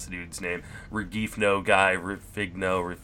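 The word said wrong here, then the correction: Rengifo: Renjifo. Luis Renjifo. Just the G is an H.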